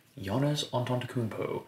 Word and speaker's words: Giannis Antetokounmpo: Seth Curry, Giannis Antetokounmpo.